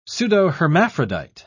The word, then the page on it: pseudohermaphrodite: British English